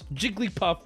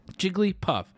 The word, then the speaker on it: Jigglypuff: I just want everybody to know that's the kind of person who hears Jigglypuff with four syllables. – Look at the chat. Oh, this is such a beautiful sight. I'm so proud of you motherfuckers. The loudest minority in town gets dunked on yet again. – 67% to 32. Oh, I think it's settled, boys. Where do you even go from there? It's basically cran at this point, three syllables, but it's okay to say with four.